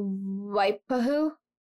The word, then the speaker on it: Waipahu: So they theorize that he also likely worked in the area of Waipahu.